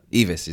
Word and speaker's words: Eavis: So I've got a quote here from, I don't know how to pronounce his last name, but Michael Eavis. Is it Eavis? – Yeah, that's it. – Eavis, is it? – Yeah.